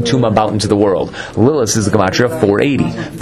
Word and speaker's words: Lilith: He wanted to bring up Sam, which is the name of the Satan, Samech Mem, which is a gematria of 100, and Lilith, Lilith, I'm not saying it with the th, because that's how it's supposed to be pronounced.